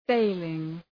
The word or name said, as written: failing